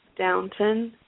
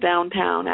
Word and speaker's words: Downton: I thought it was Downtown Abbey, but. – Me too. And I'm like, oh. It's Downton.